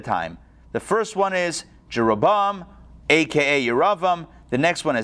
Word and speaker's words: Jeroboam: So I think in the English it's pronounced Jeroboam, Ahab, and Manasseh. However, it's Yeravam, Achav, and Manasseh in the Hebrew.